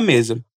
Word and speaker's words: mesa: Que em português quer dizer, você pode deixar as suas coisas sobre a mesa. Aqui, quero chamar a sua atenção para a pronúncia do S, nas palavras cosas e mesa. O som é de S mesmo, não de Z como em português.